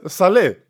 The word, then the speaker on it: Saleh: Guck mal, es ist der Pronunciation sagt S-A-L und dann U-H. Und dann habe ich jetzt Amerikaner angeguckt. Da sagen aber auch Leute Saleh.